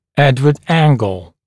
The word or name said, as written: Edward Angle